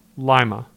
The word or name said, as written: Lima